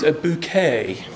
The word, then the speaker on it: Bucket: As you remember, the character played by Patricia Routledge, Mrs. Bucket, yes, it is spelled B-U-C-K-E-T, which she insists must be pronounced a bouquet.